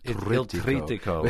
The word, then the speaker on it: Il Trittico: Il Trittico.